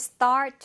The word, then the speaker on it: start: We should start doing that too. 그러면서 should, 트 발음 잘 안 들렸고 start에서도 역시나 트 발음 잘 안 들렸습니다.